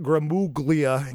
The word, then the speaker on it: Gramuglia: Ant Gramuglia, in case you're trying to remember how to spell it, but the G is silent.